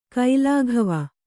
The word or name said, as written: ಗಲ್ಲಗಿವಿ